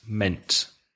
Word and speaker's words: meant: Mean, meant. So what does it mean or what did you mean? I meant that. Be careful because there are two vowels in the spelling, M-E-A-N-T, one vowel sound, eh, meant.